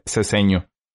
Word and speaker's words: Ceseño: No, qué, bueno que soy mexa y mi apellido Rangel y mi segundo apellido, creo mucha gente no lo sabe, Ceseño.